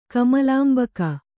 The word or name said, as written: ಕಮಲಾಂಬಕ